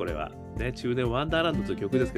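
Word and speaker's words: 中年不思議国: 中年ワンダーランド検索してみてください。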